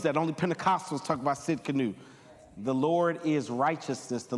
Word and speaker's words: Tsidkenu: The T is silent. Say Tsidkenu.